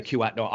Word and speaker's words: Kuat Noi: I thought, is it Kiat? Is that how you say it? Noi? Sorry, how do you pronounce it? – Kuat Noi, I think. – Yeah, Kuat Noi.